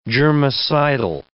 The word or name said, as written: germicidal